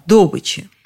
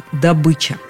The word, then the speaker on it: добыча: Вообще-то «добыча» звучит именно как «добыча». В орфоэпическом словаре под редакцией Аванесова, в словаре ударения Резниченко «добыча» приводится как единственно верный вариант. По мнению языковедов, у человека, который хочет говорить правильно, только один вариант с ударением на «ы».